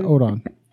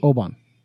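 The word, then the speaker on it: Obon: Is it Oban Festival, I think, isn't it? How you say it? Or is it Odon? – B, with a B. – Oban.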